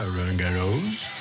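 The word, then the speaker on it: Roland-Garros: Το πήγε με προφορά, Ρολάν Γκαρός.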